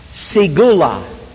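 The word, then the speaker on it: segula: And that word is pronounced sigula. Sigula.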